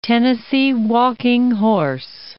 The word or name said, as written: Tennessee walking horse